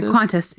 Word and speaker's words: Qantas: Yeah, Qantas.